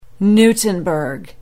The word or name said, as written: newtonburg